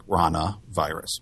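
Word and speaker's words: ranavirus: And I switched to rhinovirus. I tried to give them a cold, I guess. – Well, that's actually what their clinical signs are. So it's spelled R-A-N-A, like the frog, rana virus.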